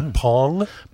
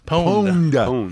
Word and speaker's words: pwned: Did you say Pong? – Pwned. – How'd you spell that? – P-W-N-E-D.